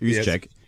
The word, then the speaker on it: Juszczyk: Juszczyk. Is that how you say it? – Yeah. I think pretty close. – Wow. – Pretty close. I mean, I'm not even 100% sure, but I think it's Kyle Juszczyk. Juszczyk. Juszczyk. There you go.